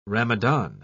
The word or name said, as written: Ramadan